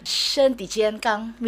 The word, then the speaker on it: 身体健康: 身体健康 means body healthy lah, strong.